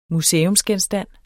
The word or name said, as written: museumsgenstand